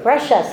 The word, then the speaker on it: Brescia: I've never heard of Brescia. Is it Brescia? – Brescia. Somebody had that pronunciation right.